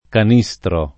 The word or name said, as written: Canistro